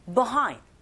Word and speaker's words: behind: 그래서 우우 running 혀가 어디에도 닿지 않게끔 하시고 behind로 들리는 경우도 있지만 빈번하게 원어민의 입에서 나오는 소리는 behind, 뭐, be라고 안 들리고 그냥 아주 간략하게 behind 이렇게 뒷부분에 강세 줘서 발음한다라는 거 그거 기억하면서 그렇게 흉내내 보세요.